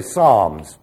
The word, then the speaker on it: psalms: P-N-E-V-M-A. We say pneuma, but there are no silent letters in Greek. Pneuma. We say psalms. Psalms in English should be spelled S-A-M-S. Psalms. But someone would say Sam's. If you are correct, you would say Psalms with a little oo on the end.